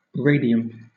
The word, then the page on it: radium: British English